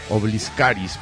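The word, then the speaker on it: Ne Obliviscaris: Este es el Despeñadero y bueno, vámonos con una banda, Neo Blisquaris, ándale. Así se pronuncia, Obliscaris, perdón.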